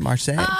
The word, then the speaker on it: Marseille: Is that how you say it? Marseille? – Sounds good.